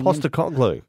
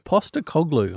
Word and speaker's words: Postecoglou: Postecoglou. Postecoglou.